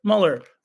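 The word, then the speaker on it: Müller: I got to pronounce the umlaut. I'm being corrected on my umlaut pronunciation. Luis Muller. There you go. Is it Muller or Mueller? Mueller? Muller.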